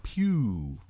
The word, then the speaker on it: Pugh: I'm going with Pugh. – Pugh. Like Hugh, but with a P? – Yeah. – Okay. Florence Pugh.